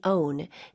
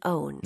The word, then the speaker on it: own: Time is something we use, not something we own. – 네. 쌤, 여기 own, O-W-N 발음 한 번만 다시 부탁드릴게요. – Own. Own. – 네. 이거 입모양을 벌리면 원처럼 되기 때문에 살짝 주의하셔야 돼요.